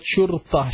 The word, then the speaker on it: شرطة: But I'm talking about شرطة. شين را طا and round تا. شرطة. شين را طا and round تا. Try to pronounce it. – شرطة.